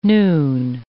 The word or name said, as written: noon